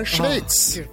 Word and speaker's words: Schweiz: Det är ah, Schweiz. Ah, Korrekt uttal. Det här i ah! Schweiz.